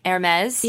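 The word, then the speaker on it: Hermès: Like, the Hermes, am I saying that right? – Hermes?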